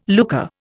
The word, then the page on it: lucre: Indian